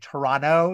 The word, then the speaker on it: Toronto: This movie was filmed at Beth Sedek in Toronto, or if you're from Canada, it's actually Toronto. You don't pronounce that second T.